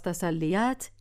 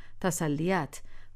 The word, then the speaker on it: تسلیت: پس تسلیت یعنی تسلی دادن و شکل درست تلفظ آن در اصل هم همین است، تسلیت و نه تسلیت.